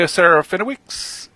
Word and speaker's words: Serafinowicz: With Peter Serafinowicz, I think I'm saying that right, as The Tick. – And okay, yes.